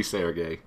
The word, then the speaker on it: Sergei: It's totally Sergei. – Okay, just check. – S-E-R-G-E-Y. Sergei is probably correct. The hard G that I like to put into words is totally not a thing.